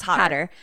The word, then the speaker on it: Hodder: Who was the nicest and who gave me the infamous fucking Jason mask that was signed by Kane Hodder, Hoder. I don't know how to say his fucking name every time. I'm always like, meh. No, I think it's Hodder. – Hodder.